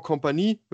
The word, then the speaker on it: Kompany: Wenn man auf den Platz gucken, beim Bayern München, da muss man sagen, erstmal Trainer, auch ein alter HSV-Bekannter natürlich, Vincent Kompany, oder Vincent Kompany, wenn man es Französisch aussprechen möchte.